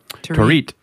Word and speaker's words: Torit: But then fast forward to 2015, these two learned that there was a team being formed to send to South Sudan in Torit. I think I pronounced that correctly. Correct me. – Torit. – Torit. I pronounced it incorrectly.